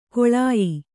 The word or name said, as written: ಕಾಳೇಯ